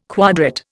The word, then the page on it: quadrat: Indian